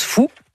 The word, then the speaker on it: Faou: Allez, moi, je m'occupe de Frédéric, qui est donc habitant du château du Faou, mais qui se prononce Fou.